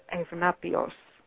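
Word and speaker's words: Evnapios (Ευνάπιος): So we know her story through another philosopher, another Neoplatonist called Evnapios. That's the Greek pronunciation.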